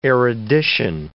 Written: erudition

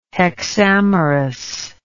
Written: hexamerous

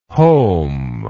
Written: heaume

